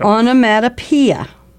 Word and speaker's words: onomatopoeia: Well, you threw me off with that pronunciation, onomatopoeia. – That's how you pronounce it. – I don't think so. – Onomatopoeia. Onomatopoeia. – So, O-N-O-M-P. No, O-N-O-M-A.